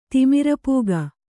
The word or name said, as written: ತಿಮಿರಪೂಗ